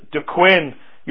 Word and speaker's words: Duquesne: It started among students and faculty, and I'll pronounce this the way that I think it's to be pronounced, and that is Duquesne University. It's a French word, D-U and then Q-U-E-S-N-E.